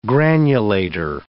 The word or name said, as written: granulator